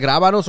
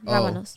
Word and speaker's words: rábanos: Is it grábanos or rábanos? – No, with an R. Rábanos.